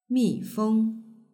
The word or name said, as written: mifeng